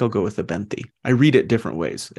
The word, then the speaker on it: Abenthy: Is that how you pronounce it? Abenthy? – Yeah.